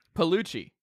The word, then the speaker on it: Piellucci: Piellucci.